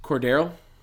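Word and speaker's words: Cordarrelle: Yeah, Cordell Patterson looked rough, and that's their guy. – How do you say his name? – Cordero. – Okay. You say it so fast, it sounds like Cordarl?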